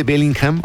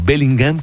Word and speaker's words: Bellingham: René Sarmiento Lozada, ¿no sabes cómo me acordé de ti con este Bellingham o Bellingham, como se pronuncia a la inglesa?